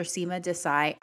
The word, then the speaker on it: Seema Desai: You can find Dr. Seema Desai on Instagram at dr. Seema Desai, and her name is spelled S E E M A. Last name is D E S A I.